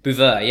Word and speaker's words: Buveur: Is it pronounced Bouveau? – Bouveau, yeah. Bouveau. So French for drinker,